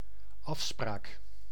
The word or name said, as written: afspraak